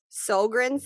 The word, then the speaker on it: Sjogren: Sjogren syndrome, but it's spelt how you would not expect it. S J O G R E N syndrome.